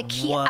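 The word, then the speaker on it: Kiiroo: Okay, it's called the Kiro. I want to say it right. – It's K-I-I-R-O-O.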